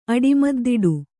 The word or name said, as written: ಅಡಿಮದ್ದಿಡು